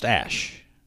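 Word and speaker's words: Æ: And, of course, what you just heard was the guy telling Joe Rogan on a podcast that really AE is pronounced Ash, while crediting his girlfriend for coming up with the bulk of the moniker.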